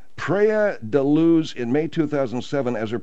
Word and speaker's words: Luz: Madeleine from Leicester was aged 3 when she vanished from a holiday apartment in Praia da Luz, in May of 2007. I don't know if I'm pronouncing that right or not. – It's Luz. – Yeah, it's more like a Praia Luz.